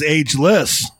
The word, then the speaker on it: ageless: Oh, yeah, Thomas is ageless. That's a tricky G because it's a soft G.